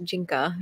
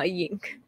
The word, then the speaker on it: Jinka: I think phonetic, it's pronounced Jinka. – Got it. – Not yink.